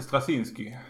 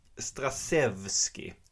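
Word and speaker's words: Strazewski: Och den är skriven av en kille som heter Len Strasevski. Jag är inte helt hundra på att det är så det uttalas, men vi säger det. Och inte Strasinski. – Nej, Strasevski.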